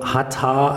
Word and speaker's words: Hatha: Ähm, Yoga, oder wenn wir den Begriff Hatha sehen, besteht aus zwei Silben, Ha und Ta.